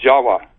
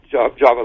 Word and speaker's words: Java: The word in Java, the island of Java, you know, is pronounced Jawa, actually. Indonesians will pronounce it Jawa, not Java like we do.